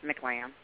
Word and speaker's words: McLamb: Yes, McLamb.